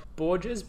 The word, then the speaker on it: Bourges: So they've got Bourges. B-O-U-R-G-E-S. I don't know where that is. I'm assuming in France. – Sounds like bourgeois.